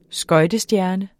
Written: skøjtestjerne

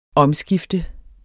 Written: omskifter

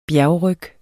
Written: bjergryg

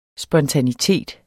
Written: spontanitet

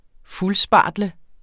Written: fuldspartle

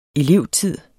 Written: elevtid

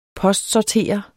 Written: postsorterer

